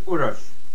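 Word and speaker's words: Uroš: Hello, my name is Uros. The last two letters of my name are pronounced like last two letters in the word fish.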